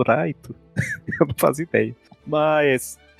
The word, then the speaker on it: Light: É o mesmo motivo que eu chamo o protagonista do Death Note de Raito ao invés de Light. – Então, eu também chamo de Raito também. – É, a pronúncia japonesa.